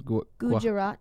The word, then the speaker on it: Gujarat: Gujarat. – Gujarat, yeah, something like that.